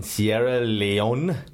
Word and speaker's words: Sierra Leone: Okay, Blood Diamond is set in the early 1990s in Sierra Leone.